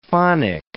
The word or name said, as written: phonic